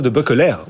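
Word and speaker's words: de Beukelaer: Christophe de Buckeler, on prononce son nom comme il faut, belge, ouais.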